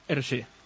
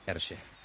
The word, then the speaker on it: Hergé: Yo le decía Hergé. El otro día alguien de la audiencia me decía, creo que se pronuncia como Hergé. – Hergé. – Porque era por las iniciales de su nombre real al revés, su nombre verdadero, porque era un seudónimo, Hergé.